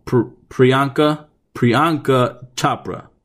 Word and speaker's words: Priyanka Chopra: Priyanka Chopra.